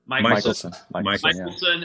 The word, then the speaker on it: Michelson: It's not Mickelson like the golfer. – Michelson.